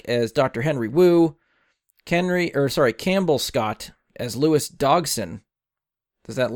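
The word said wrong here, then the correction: Dodgson: I mean, you said it wrong. Because it's Dodson. Dodson. We've got Dodson here.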